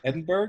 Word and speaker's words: Edinburgh: Ooh, ooh, how about that pronunciation? – Edinburgh, is that not right? Yeah, it's right.